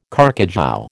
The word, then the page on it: carcajou: American English